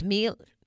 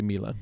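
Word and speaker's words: Miele: I've always said Mila, but it's Mille. – In the U.S., we say Mila.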